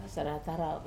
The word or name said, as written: sarata rau